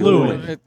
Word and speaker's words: Llewyn: They like that like name, Patrick, Llewellyn, don't they? – Yes. – It's actually Llewyn. – It's different. – Llewyn. Yeah. Sorry. My bad. – They cut a syllable off.